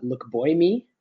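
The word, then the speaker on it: Lookboonmee: It's very confusing because it's actually pronounced Loma Lukboimi.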